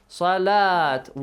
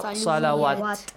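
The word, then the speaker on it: salat (صلاة): It will be pronounced as salat. Waw on the top of waw, there is dagger alif, not salawat.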